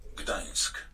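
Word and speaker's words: Gdańsk: Tengo la pronunciación de Dansk. Muy bien, me eh, interesa. la busqué en internet. Vamos a ver si se puede escuchar. A ver. Gdansk. – Gdansk. Gdansk. – Bueno, ahí está, perfecto.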